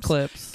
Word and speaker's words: Klipsch: Which I mean isn't terrible, but if you don't know what we're talking about Clips. I think that's how you say it. – Yeah, Clips. – It is Clips. – K-L-I-P-S-C-H.